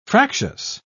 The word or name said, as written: fractious